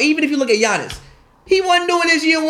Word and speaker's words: Giannis: Yo, you finally pronounced his name as Giannis.